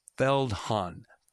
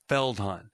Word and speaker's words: Feldhahn: And her last name is pronounced, I had to have her, uh, by the way, pronounce it for me Feldhahn. Feldhahn.